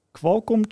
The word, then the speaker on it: Qualcomm: Dus we hadden volgens mij een, een, een router met een Qualcomm chipset, zeg ik het goed, Thomas?